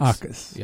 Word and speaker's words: Jacas: J a c a s is uh, pronounced Akas. Akas.